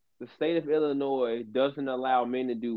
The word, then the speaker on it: Illinois: The S is silent. It's my home state, so get it right, Illinois.